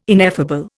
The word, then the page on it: ineffable: Indian